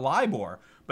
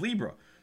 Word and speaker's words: LIBOR: And so then he had a question about LIBOR, but he mispronounced it as Libra. So he started talking about Libra when he meant LIBOR, which is London Interbank Offer Rate. But he was calling it Libra because the people before him had been talking so much about Libra.